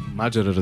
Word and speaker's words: Маджера: Абе, верно ли Маджера е правилното ударение? – Разбира се. – Маджера, разбира се.